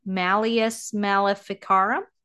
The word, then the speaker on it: Malleus Maleficarum: So with Germany being having the most, the publication of, what is it, Malleus Maleficarum? – I think it's pretty good pronunciation.